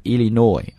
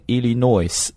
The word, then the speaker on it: Illinois: in court, in the US state of Illinois, 啊注意这个单词伊利诺伊州不要读成 Illinois, 那个 S 不发音 ,Illinois, the US state of Illinois, 那么他为什么会出现在法庭上呢？